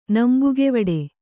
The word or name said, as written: ನಂಬುಗೆವಿಡಿ